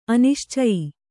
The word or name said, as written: ಅನಿಶ್ಚಯ